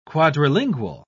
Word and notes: quadrilingual: British English

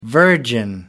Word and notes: virgin: American English

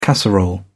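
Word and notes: cassette: British English

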